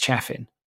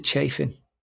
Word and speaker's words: Chaffin: I made the mistake of calling him Sean Chaffin because it's got two Fs in it. And so if you're English, that's Chaffin. But he's from Texas, and you pronounce it Chaffin.